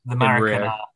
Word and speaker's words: Maracanã: The Maracanã.